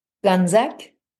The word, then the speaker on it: Gonzaque: Gonzaque.